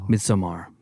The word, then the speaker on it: Midsommar: We'll be joined by Will Poulter, star of Ari Aster's Midsommar. I think that's how you pronounce it. – Midsommar.